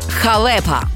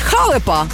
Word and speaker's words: халепа: Наголос на другому складі – халепа. Бо якщо скажеш халепа, то вийде щось нове, але зовсім не українське.